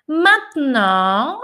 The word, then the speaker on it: maintenant: Ma, essa é uma palavra legal também de trabalhar a pronúncia, porque aqui você tem o man, igual mão, né? Man. Aqui você tem o e mudo, mat. E aqui você tem o nasal on, Maintenant. Você tem duas nasais aqui, o man e o non.